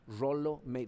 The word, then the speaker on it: Rollo May: Rolo May. se escribe con doble L, entonces se leería Rollo May, pero se pronuncia Rolo, ¿ok?